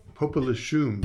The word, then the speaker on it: Popelouchum: But I'm going to let you say it because I say it wrong. – Popelouchum.